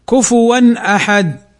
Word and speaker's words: كفوا أحد: الهمزة الألف في أحد، الألف في أحد، فنطقها هكذا مظهرا للحرف كفوا أحد، كفوا أحد.